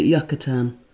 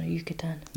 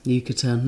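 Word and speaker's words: Yucatán: I've always pronounced it Yucatan. – No, Yucatan. – Yucatan, now I know.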